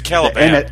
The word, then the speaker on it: Kalibak: I'm gonna see how many times you pronounce his name wrong. – It's Stephen Merchant. – No, the character. – Oh, is it Caliban? I'm sorry, I'm saying Kalibak, aren't I? Mm. Yeah, sorry. It's Caliban.